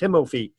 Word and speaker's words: Timofey: Oh yes, and he doesn't have a speech impediment. That is pronounced Timofey.